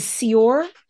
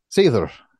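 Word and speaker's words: seiðr: Is Sior how you say it? – Seether. – Seether? – Seether, yep. – Oof. Okay, so Old Norse Seether.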